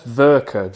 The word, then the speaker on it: Werke: Yeah, it's Werke.